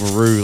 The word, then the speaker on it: Roo: I'm giving a lot of spelling this morning, but it's spelt Roo like kangaroo. It's like Andrew, R-O-O.